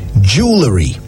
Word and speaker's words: jewellery: Jewelry.